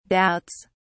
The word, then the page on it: doubts: British English